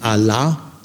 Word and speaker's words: Allah: Also das islamische, die islamische Bezeichnung Allah, zweiter Ton, zweite Silbe betont, für Gott liegt auf der Ebene El.